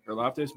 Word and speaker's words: Karlaftis: My number three is George Karlaftis. Did I say that right? – Yep. – Karlaftis, Purdue defensive end.